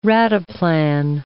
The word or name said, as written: rataplan